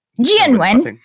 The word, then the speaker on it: Jianwen: Jianwen.